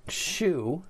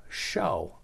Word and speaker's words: shew: So some people like to pronounce it shew because that's the way it's spelled, S-H-E-W, but it really is just a British spelling of the word to show thyself.